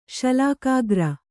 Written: ಶಲಾಕಾಗ್ರ

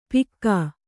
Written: ಪಿಕ್ಕಾ

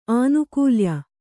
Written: ಆನುಕೂಲ್ಯ

